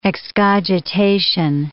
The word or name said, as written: excogitation